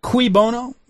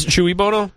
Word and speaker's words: cui bono: And the thing is, this actually see when you do the what is it, the cui bono or cui bono, how do you say that, cui bono? – I think it's chewy bono.